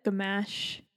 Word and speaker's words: Gamache: And I read that Gamache.